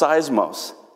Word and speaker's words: seismos: Actually, the word storm, as we have it translated in our English translations, is a Greek word that's pronounced seismos. That's where we get our word seismic activity, like earthquakes.